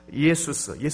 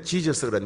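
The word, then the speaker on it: Iesus: 영어는 지저스 그랬는데 영어 발음보다도 우리 한글 발음이 예수님의 원래 발음에 더 가까워요. 그러니까 굳이 지저스 이런 말 쓰지 마세요. 무식한 표현입니다. 예수라는 발음이 더 훨씬 더 정확한 표현입니다.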